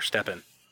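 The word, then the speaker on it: Stephan: Like, I know Stefan is from UFC, or maybe is it pronounced Steven or Stefan? – It's Stefan. Stefan Bonner.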